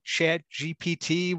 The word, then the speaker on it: ChatGPT: I actually watched his video on ChatGPT, whatever the hell it is.